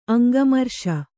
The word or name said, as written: ಅಂಗಮರ್ಷ